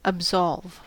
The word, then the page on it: absolve: American English